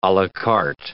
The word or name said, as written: a la carte